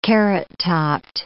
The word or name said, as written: carrottopped